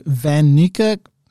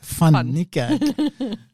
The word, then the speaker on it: Van Niekerk: But it's actually a Dutch surname. So Van Niekerk, that's how we pronounce it here. It's the proper pronunciation is Van Niekerk.